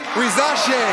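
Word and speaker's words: Risacher: With the first pick in the 2024 NBA draft, the Atlanta Hawks select Zachary Risache from Lyon, France, and France's JL Boron-Bresse. – All right, now give me Malika Andrews. – Zachary Risache, the 19-year-old, is headed to Atlanta.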